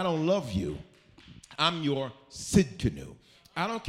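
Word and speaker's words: Tsidkenu: He's Jehovah Sid Canoe. The T is silent. Do not say to Sid Canoe.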